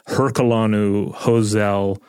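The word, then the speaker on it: Herculano-Houzel: Now, I do want to mention, you're hearing this last name. Sometimes you'll hear people pronounce it Herculanu Hozel. – If you're trying to look it up, it's Herculano-Hozel.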